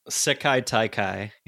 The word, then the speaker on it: Sekai Taikai: We have their best karate yet to come with the Sekai Tekai or whatever. – Yeah. – I'll never pronounce that correctly until they say it a million times. – Sekai Taikai. Yeah.